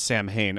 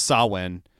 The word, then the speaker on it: Samhain: But it's not pronounced Samhain. Of course it's not because it's Celtic. It's pronounced Samhain.